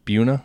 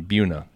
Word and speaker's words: Buna: And the town that we're just outside of is pronounced Buna. Is that right? I thought when you read it, I just said Buna. But someone down here corrected me around Houston. Buna.